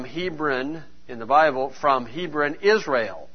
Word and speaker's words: Hebron: And to them that were in, the way it's pronounced in the Bible is Hebron. Okay, here we pronounce it Hebron.